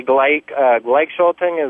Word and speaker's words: Gleichschaltung: Yeah, and the idea of the Gleichschaltung, is that how it is pronounced? – Yeah.